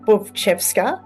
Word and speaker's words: Butchveska: So it's three syllables, Butchveska, okay.